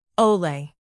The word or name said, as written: ole